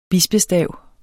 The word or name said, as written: bispestav